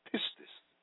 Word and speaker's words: pistis: The word faith in the Greek is pistis, pronounced pistis, which is derived from the Greek word paito, meaning to persuade or persuasion.